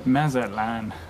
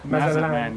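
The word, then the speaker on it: Mazatlán: How do you say this town's name? – Mazatlan. – Mazatlan, yeah.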